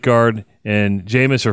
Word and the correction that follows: Jameis: Hymus, not Jameis.